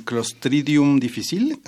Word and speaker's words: Clostridium difficile: Creo que sería importante hablar sobre el Clostridium difficile, así se pronuncia.